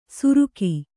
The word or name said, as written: ಸುರುಕಿ